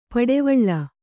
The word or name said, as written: ಪಡೆವಳ್ಳ